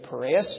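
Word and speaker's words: Pareus: I believe David Piraeus is the pronunciation of his name.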